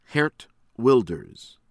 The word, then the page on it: Geert Wilders: American English